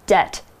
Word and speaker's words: debt: Now, here's another word that we need to know for this sentence debt. D E B T. We don't say the B, it's a silent B.